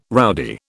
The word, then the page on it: rowdy: British English